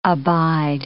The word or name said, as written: abide